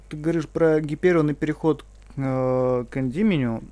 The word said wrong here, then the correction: Эндимион: Как Эндимион. – Эндимион. Эндимион, правильно, ударение, да.